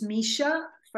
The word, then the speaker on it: Misha: It's my name and my surname, which is Misha, first name, second name Milovanovich.